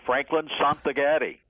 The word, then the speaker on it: Franklin Santagate: Actually, it's like spaghetti. Franklin Santagate.